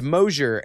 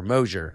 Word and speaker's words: Mosier: And then for my last name, it's Mosier, and really the easiest way for me to tell people is it's closure with an M. Like, it rhymes with closure, Mosier.